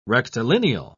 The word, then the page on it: rectilineal: British English